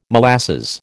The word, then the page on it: molasses: American English